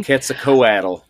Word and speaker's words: Quetzalcoatl: Quetzalcoatl. There you go. You can say it like that.